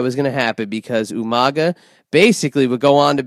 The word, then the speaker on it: Umaga: And of course, you have to say it like Armando would say, Umaga,